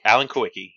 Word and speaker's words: Kulwicki: Alan Kulwicki, yep.